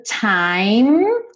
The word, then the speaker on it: time: Time. Sem pronunciar a letra E. Então, não vai falar what's the time, ok?